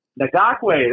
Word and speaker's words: Ngakoue: Yannick Ngakoue, I can never pronounce his name, a phenomenal pass rusher. Ngakoue, there you go.